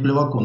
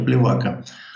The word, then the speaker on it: Плевако: правильно говорить Плевако, насколько я знаю, не Плевака,